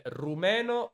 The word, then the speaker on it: rumeno: Però in italiano per complicare le cose ci sono due modi di dirlo, cioè rumeno o romeno.